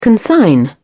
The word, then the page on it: consign: British English